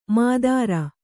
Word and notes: ಮಾದಾರ: mādāra; Kannada

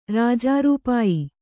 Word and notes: ರಾಜಾರೂಪಾಯಿ: kannada

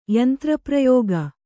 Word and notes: ಯಂತ್ರಪ್ರಯೋಗ: kannada

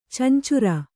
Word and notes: ಚಂಚುರ: cancura

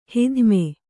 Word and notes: ಹಿಧ್ಮೆ: hidhme

